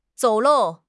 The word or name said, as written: 走漏